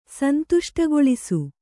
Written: ಸಂತುಷ್ಟಗೊಳಿಸು

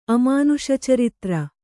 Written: ಅಮಾನುಷಚರಿತ್ರ

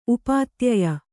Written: ಉಪಾಧ್ಯಾಯೆ

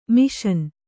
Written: ಮಸಣ

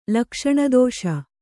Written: ಲಕ್ಷಣದೋಷ

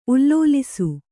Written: ಉಲ್ಲೋಲಿಸು

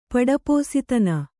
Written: ಪಡಪೋಸಿತನ

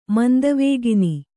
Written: ಮಂದವೇಗಿನಿ

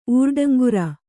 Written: ಊರ್ಡಂಗುರ

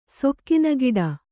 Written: ಸೊಕ್ಕಿನಗಿಡ

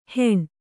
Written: ಹೆಣ

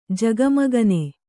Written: ಜಗಮಗನೆ

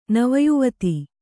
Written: ನವಯುವತಿ

